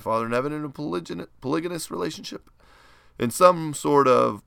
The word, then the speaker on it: polygynous: Or a full and equal partner with Father in heaven in a polygynous. Is that how you say it, Jeff? – Polygynous.